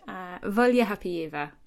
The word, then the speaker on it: Volha Hapeeva: A writer and translator, obviously, including of the Swiss/German poet Nora Gomringer and the Belarusian poet Volha Hapeeva. Have I pronounced that right? – Volha Hapeeva. – Thank you. – But you were close. You were very close.